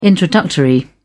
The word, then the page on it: introductory: British English